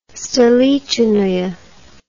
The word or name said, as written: stolichnaya